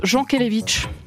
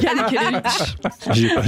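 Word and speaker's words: Jankélévitch: Et question prononciation, tu préfères te taire à tout jamais ou dire une fois pour toutes à Simon que ça se prononce Jean Kelevitch ? Et c'est vrai, c'est... Yann Kelevitch.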